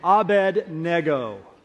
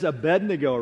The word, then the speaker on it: Abednego: I know what you're thinking. He mispronounced that. No, I didn't. You probably learned it as Abednego, right? Abednego. When I grew up, I think my parents inverted the D and the N, and I thought it was Abednego, until I read the story and went, they misled me all these years.